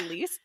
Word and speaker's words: Lys: That's L-Y-S. And I did actually look up the pronunciation. – Yeah. – Lys, L-Y-S.